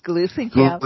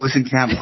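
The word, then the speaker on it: Gluesenkamp: Finally, I want to talk about the 3rd District in Southwest Washington, that one between MAGA candidate Joe Kent and I'm going to mispronounce her name. Glutenkamp? Glusenkamp. – No, Glusenkamp. – Glusenkamp Perez. it's easier to remember it as Glutenkamp. – no, it's Glusenkamp.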